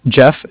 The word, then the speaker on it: Jeff: Jeff, J-E-F-F. And I'm calling from the Bank of Cardiff. Cardiff is spelled C-A-R-D-I-F-F.